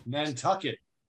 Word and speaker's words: Nantucket: Ok, entre 1984 y 1989 hubo un trabajo muy fuerte y estabas en una banda que se llamaba Nantucket. ¿Cómo se pronuncia? – Nantucket.